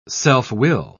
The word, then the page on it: self-will: British English